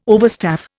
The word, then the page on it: overstaff: Indian